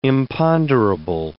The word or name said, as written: imponderable